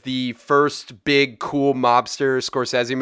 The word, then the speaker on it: Scorsese: By the way, it's pronounced Scorsese for anybody out there who was wondering if it's Scorsese. It's Scorsese. But tomato.